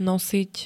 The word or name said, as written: nosiť